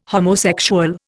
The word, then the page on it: homosexual: Indian